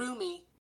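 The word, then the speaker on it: rheumy: Chicago's on Lake Michigan, and how would you say that? Ryumi. – Sorry, I just went to the Google. I don't have the Oxford one up, which would show me how to say it. It's really weird that this specific dictionary sentence example has roomy eyes. – Yeah, that's what mine says too. – Roomy. – Roomy. – Roomy, roomy, yeah.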